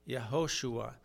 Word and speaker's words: Yehoshua: Yehoshua, Joshua.